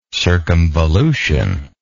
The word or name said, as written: circumvolutory